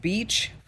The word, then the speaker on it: Bitche: It's the town of Beach, France, but it's spelled B-I-T-C-H-E.